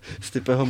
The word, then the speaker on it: Stipe: Stipeho Miočiče se vyslovuje tvrdo Stipe, takže, takže nemáš říkat Stipe.